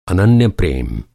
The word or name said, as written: अनन्य